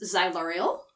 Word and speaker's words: Ziluriel: The demon's name is Ziluriel.